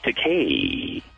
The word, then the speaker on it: Takei: Although whoever was reading the showbiz beat called me George Takei. – I think I did. – It's pronounced George Takei.